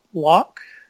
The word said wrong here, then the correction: Luick: Luick. – Luick, okay.